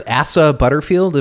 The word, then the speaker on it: Asa Butterfield: Sex Education it stars Asa Butterfield.